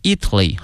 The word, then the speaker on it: Italy: 意大利这个词啊，同学们要小心了，它的名词和它的实用词呢，这个发音同学们一定要小心了。意大利呢是 Italy 啊，国名。如果说意大利的或意大利人叫 Italian，哎，所以说这个发音还是有区别的。 Italy Italian by a couple who opened a restaurant in Florence, the dish has become very popular 啊。